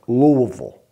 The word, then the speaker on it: Louisville: And that's how you say it, Louisville.